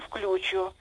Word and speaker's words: включу: Но мне так хочется сказать, что я сейчас сначала вам позвоню, потом приеду и включу. – Замечательно. – Скажите, пожалуйста, как надо правильно говорить? – Вы правы. Норма не изменилась. Она такая же, как и в словаре, которым вы пользуетесь.